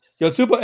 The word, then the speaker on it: Yotsuba: And, you know, because it's Yotsuba, the little ampersand, and then an exclamation point. So I pronounce it as Yotsuba and because that feels right.